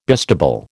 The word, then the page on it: bistable: American English